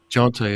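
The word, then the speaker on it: Johntá: I don't know if I'm pronouncing his name right, but the other writer, Jonta. – Jonta, yeah, there you go.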